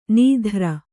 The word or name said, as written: ನಾದಾರ